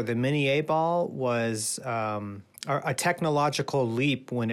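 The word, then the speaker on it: Minié ball: They called it mini ball, but technically it was probably called mini A ball because the inventor of it, Claude Minier, invented the ball.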